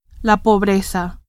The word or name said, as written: pobreza